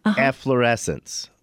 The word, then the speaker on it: efflorescence: Efflorescence.